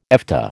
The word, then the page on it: Efta: American English